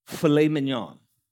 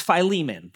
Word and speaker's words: Philemon: And we're going to go through a short book in the New Testament of filet mignon. I don't know if you've ever seen that. It's Philemon. That's how to say it.